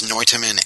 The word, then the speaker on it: Noitamina: I know it's animation backwards. How do you say it? How do you pronounce it? – Uh, it's pronounced Noitamina, I believe.